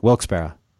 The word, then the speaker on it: Wilkes-Barre: Yeah, Wilkes-Barre.